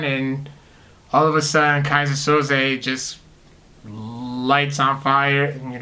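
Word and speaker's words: Keyser Söze: And also on the other side of that same comic, there was Kaiser Soze, I hope I'm saying that right. – Yep.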